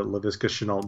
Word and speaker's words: Laviska Shenault: Are you referring to Laviska Shenault Jr.? – Good job saying that name.